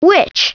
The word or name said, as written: witch